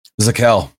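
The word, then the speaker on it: Zakelj: Zakel. That's how you pronounce it. The J is silent.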